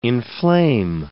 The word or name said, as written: inflame